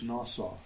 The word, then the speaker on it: nasa: That word is the Hebrew word is pronounced nasa, if you're interested.